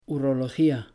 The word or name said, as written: urología